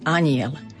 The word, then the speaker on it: anjel: Z hľadiska výslovnosti dávajme si pozor na slovo anjel. Veľmi často ho tu máme spomenuté. Vždy je to mekké, anjel.